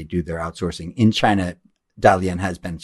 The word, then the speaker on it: Dalian: Dalian is the other place? – Dalian. – Dalian. I'm pronouncing correctly.